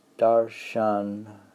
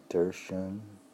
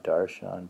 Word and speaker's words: Darshan: Had he been only a poet, Darshan. Remember how the pronunciation was like Darshan? Not Darshan, but Darshan.